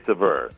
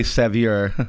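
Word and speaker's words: Saveur: But I think it's pronounced, I say Sever, which is about as close to the proper pronunciation. – Yeah, that's what Kimberly teaches me. – Sever. Mm-hmm. – And most people say Sevier. – Yeah. Or Savior.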